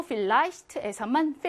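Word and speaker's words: vielleicht: 그리고 vielleicht에서만 viel, 짧게 이.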